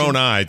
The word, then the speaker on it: Moroni: It's Moroni, technically, but it's just funny to hear other people say it in a different way.